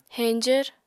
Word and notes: heñjvr: Apatani

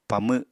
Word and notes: pamw: Apatani

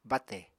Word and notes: bátè: apatani

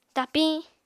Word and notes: tapiñ: Apatani

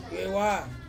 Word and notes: viewa: Ansus

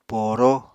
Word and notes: pooró: Apatani

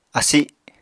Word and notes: asi: Apatani

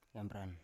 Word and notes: yambran: Biak

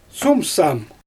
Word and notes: sumsam: Xyzyl

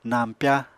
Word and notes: nañpya: Apatani

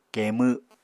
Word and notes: keemw: Apatani

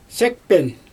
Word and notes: sekpen: Xyzyl

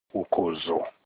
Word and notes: ukozo: Olukumi

